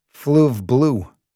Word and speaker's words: Fleuve Bleu: Yet strangely, by some logic a local historian might have explained, the small riverside city itself was still called Fleuve Bleu, pronounced in the flat nasal way of upstate New York, Fleuve Bleu.